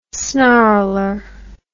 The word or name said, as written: snarler